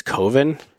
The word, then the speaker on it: Coven: I think it's Coven. Why would they pronounce it like oven? That doesn't make any sense.